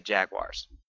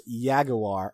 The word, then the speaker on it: jaguar: It might not have been the original, but the current and correct pronunciation is jaguar. And on top of that, if you're the kind of person that's going to hear somebody else say jaguar and then say it's pronounced yaguar or whatever the fuck you want, I reserve the right to punch you in the face.